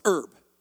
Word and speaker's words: herb: Do you say the H or not? No? So it's herb.